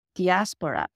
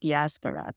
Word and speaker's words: diaspora: The word diaspora, I like to pronounce it diaspora because in Greek it's dias, but we can also call it diaspora.